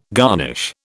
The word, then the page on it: garnish: British English